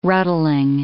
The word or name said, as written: ruddling